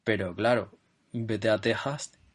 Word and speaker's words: Texas: Entonces, Texas se escribe con X, igual que México. Pero eso no significa que se pronuncie con X en castellano, sino con J.